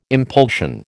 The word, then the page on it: impulsion: American English